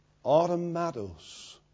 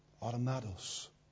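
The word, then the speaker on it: Automatos (αὐτόματος): The original Greek word that's translated of herself is pronounced this way. Automatos. Does it sound familiar? It's the Greek word from which we get the English word automatic. Automatos.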